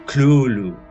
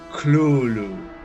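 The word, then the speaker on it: Cthulhu: Y de hecho, eh, Lovecraft escribe en su carta que debería pronunciarse como algo parecido a Clulu. con la primera sílaba fuerte, marcada y gutural.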